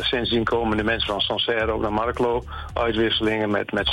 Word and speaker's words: Sancerre: En hoe moet ik het uitspreken? Sancerre? – Sancerre, ja.